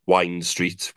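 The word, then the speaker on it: Wind Street: I mean, if anyone's coming down and wants a few drinks, Wine Street, which is pronounced as wine, but it's spelled as wind.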